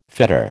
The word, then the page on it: fetter: American English